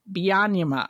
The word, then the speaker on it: Byanyima: It's Winnie Byanyima, I believe is how she says it.